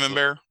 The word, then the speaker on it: Camembert: Camembert.